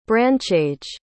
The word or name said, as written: branchage